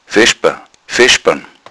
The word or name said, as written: veschban